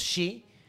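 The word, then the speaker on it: sídhe: Se escribe side. Uh-huh. Otra. – Sí. – Side y sí, dice sí, shi, güey. Sí, sí. No mames. – Exacto. Los otros dos. – O sith. Ajá. También se escribe sith y se pronuncia shi.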